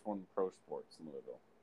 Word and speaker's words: Louisville: To be fair, it's actually pronounced Louisville. – Louisville.